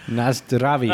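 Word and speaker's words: Na zdraví: And then they chug down a Pilsner and exclaim, Nasvatladi, or how do you say, is that how you say cheers? – Nasdravi. – Oh, Nasdravi. Nasdravi, bitch.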